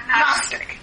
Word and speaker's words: Gnostic: Idea of Gnostic, is the G pronounced Gnostic? – No. – Okay, Gnostic, yeah.